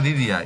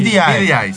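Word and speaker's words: Beady Eye: Yo pensé que se decía DDI, ojo. – Se escribe Beadi Eje. – Beadi Eje. – BDI se dice.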